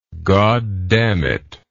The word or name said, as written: goddamnit